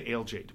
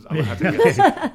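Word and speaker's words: Aléjate: Yeah, I say Alejate, but it's Alahate.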